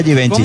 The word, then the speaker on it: Givenchy: Givenchy, así se pronuncia Givenchy.